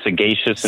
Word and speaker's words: Sagacious: We're Sagacious Insurance Services. So the way I remember it is when you think of like a geisha, but it means keen, cunning, on top of your game, you know, kind of rolling with the punches on things.